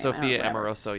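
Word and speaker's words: Amoruso: Sophia Amoroso, that's how you pronounce her name? – Sophia Amoroso, yeah.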